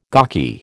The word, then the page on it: gawky: American English